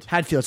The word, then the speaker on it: Hadfield: Chris Haddonfeld? – Hadfield, sorry.